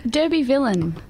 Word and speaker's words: Derbyvillian: How is the correct way to pronounce that? – Derbyvillian. – Okay. – Like the word villain.